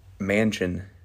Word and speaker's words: Manchan: And then I was going to make another brand and just call it Mansion but still spell it M-A-N-C-H-A-N.